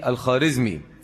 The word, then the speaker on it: الخوارزمي: وهذه الخطة صنعها جنغيز خان في قتاله مع جلال الدين الخارزمي، والخارزمي تنطق بالواو الخوارزمي، ولكن هذه الواو تسمى في الفارسية بالواو المعدولة فلا تنطق الخارزمي ولكنها تكتب بالإيه؟ بالواو الخوارزمي.